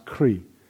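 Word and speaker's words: kṛ: and this is the root which is pronounced kri.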